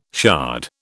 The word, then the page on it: shard: British English